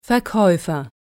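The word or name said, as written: Verkäufer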